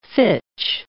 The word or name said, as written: fitch